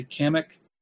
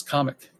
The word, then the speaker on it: Kamek: I think I've only heard people say Kamek, but I guess it's Comic, which makes sense for the way Japanese would pronounce it.